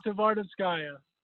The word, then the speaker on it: Tvardovskaya: And by the way, can you pronounce your last name for us? Cause we just completely butchered it. – Yeah. It's Tvardovskaya. – Tvardovskaya. – I knew it. That's what I said. – Awesome. Thanks. – Just got to say every letter.